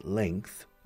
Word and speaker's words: length: Fijaos cómo se pronuncia. Length. Como si fuera una KZ al final, no GHTH. But it's KZ.